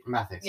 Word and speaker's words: Matthias: And also like we learned like Matthias, is that how you pronounce it? – Matthias.